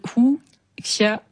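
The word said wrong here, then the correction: wuxiapian: Wu Xiapian.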